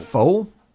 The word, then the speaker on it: pho: I think it's P-H-O. Oh, okay. – P-H-O. Isn't that pho?